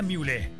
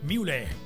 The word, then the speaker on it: Muhle: Recuerda, se pronuncia Mule, M-U-H-L-E, Mule. Visítanos en Madrid 1129, Vía Alemana o en mule.cl.